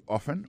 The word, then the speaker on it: often: Often times, c'est O-F-T-E-N. Times, T-I-M-E-S, alors c'est comme ça on dit ça. Soit on dit often, often non, c'est O-F-T-E-N, malgré l'écrit often, on prononce often. Alors, je crois que T-A-B-B.